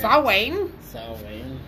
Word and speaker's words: Samhain: It's spelled Samhain, but it's pronounced Samhain or something like that. – Okay. – Samhain.